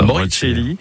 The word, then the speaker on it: Moricelli: Moricelli, c'est pas comment on le prononce. – C'est bien dit comme ça. – Moricelli.